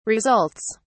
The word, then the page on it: results: British English